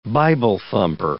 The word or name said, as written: Bible-thumper